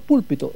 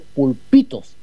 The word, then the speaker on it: Pulpitos: pero no es Los Púlpitos, es Los Pulpitos. La acentuación tiene que ir en la I, no tiene acento en la U.